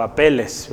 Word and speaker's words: Apeles: Apeles o apeles. Sería interesante ver ahí gramaticalmente cómo se dice, pero Apeles.